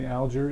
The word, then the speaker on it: Alger: I've heard lots of people say Fanny Alger. Is there a correct way to say that? – A family member said it was Alger.